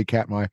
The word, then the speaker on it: Katmai: Well, I'm telling you, when you put that up, and I did accidentally pronounce it correctly, Katmai, I looked it up, and you just scroll through picture after picture after picture of bears.